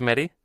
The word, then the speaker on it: Cometti: Cometti,